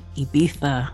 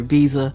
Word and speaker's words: Ibiza: All right, and the Black Music Summit is heading to Ibiza, or Ibiza for some of us U.S. people, as we pronounce it.